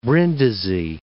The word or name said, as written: Brindisi